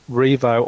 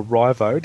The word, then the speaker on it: RIVO: Now, this one is called Rivo or Rivo, depending on how you want to pronounce it. It's actually R-I-V-O. I tend to say Rivo or Rivo.